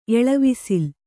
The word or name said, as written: ಎಳವಿಸಿಲ್